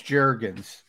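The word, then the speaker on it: Jurgens: Let's get over to the second round draft pick, Cam Juergens. Is that how you pronounce it? – Yeah, there was it is Juergens.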